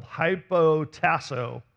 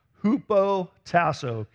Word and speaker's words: hupotasso: All right, there might be a little sarcasm in that statement, but the Greek word used here for submit, it looks like it's spelled hypotasso, but it's actually pronounced hupotasso.